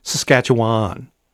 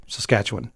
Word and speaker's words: Saskatchewan: Because a lot of people say Saskatchewan. They overpronounce that last syllable. – Why? What? How is it supposed to be said? – Saskatchewan.